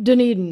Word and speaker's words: Dunedin: Is it pronounced Dunedin? – Dunedin.